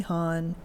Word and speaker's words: Honn: Yes, Tracy Hahn, H-O-N-N.